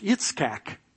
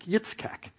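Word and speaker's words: Yitzchak (יצחק): which in the Hebrew is pronounced Yitzchak. That's how you say Isaac, Yitzchak, which, by the way, means laughter.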